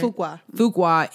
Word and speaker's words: Fuqua: And if you don't know, Antoine Fuqua, am I saying it right? – Fuqua is married to Layla Rashan, who is infamous for her roles.